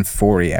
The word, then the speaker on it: Foree: So Ken Foree was in this, or I'm sorry, I think he pronounces it Ken Foree, actually.